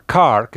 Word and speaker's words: Carr: Por ejemplo, Deborah Carr, que se pronuncia Carr como coche.